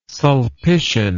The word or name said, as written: sulpician